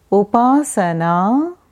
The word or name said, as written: Upasanaa